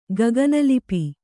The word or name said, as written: ಗಗನಲಿಪಿ